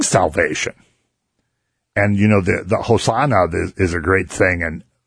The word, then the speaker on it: Hosanna: And when you say in Hebrew, when you put a big accent on the last word, on the last letter of the word, and say Hosanna, that means like save us now.